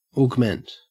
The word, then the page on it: augment: Australian English